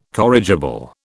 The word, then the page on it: corrigible: British English